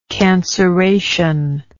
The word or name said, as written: canceration